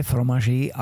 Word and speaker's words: Fromager: Und äh, unter dem Logo von Käselanger steht äh, Maitre Fromage Affineur. Habe ich richtig ausgesprochen? – Maître Fromager Affineur. – Ähm, Fromager, na ja.